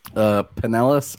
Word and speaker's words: Pinellas: Is the leader at Pineus. Have I pronounced that right? Is it Pineus? – Pinellas or Pinellas.